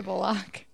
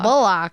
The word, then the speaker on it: Bullock: B-U-L-L-O-C-K. – Bullock. – Sounds like Bullock to me. – Yeah. Sandra Bullock. – That's Bullock. – Wait, are you kidding? – No, she is pronouncing it correctly.